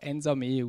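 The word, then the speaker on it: Nsamé: Da spricht man tatsächlich Ensame aus.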